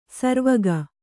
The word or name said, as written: ಸರವಕ್ಕಿ